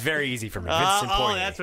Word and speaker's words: Vincent Poirier: You get Poirier's name right, but you deliberately say Bertan's first name wrong? – Well, it's like Christian Fourier, so it's very easy for me.